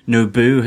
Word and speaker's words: Nobu: And even when Nobu has I've said it correctly this time. Not Nobu, not Queen Amidala's planet, but actually the character in Daredevil.